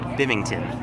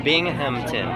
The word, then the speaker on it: Binghamton: Hey, this is Tim Nordwin from the band OK Go, and you're listening to WHRW Bimington. Is that right? – Bimington. – How do you say it? – Binghamton. Yeah. – Oh, I spelled it wrong. How do you spell it? – B-I-N-G. – Oh. – H-A-M. – Oh. Why didn't you tell me? – Sorry, man. – Binghamton. – Yeah, Binghamton.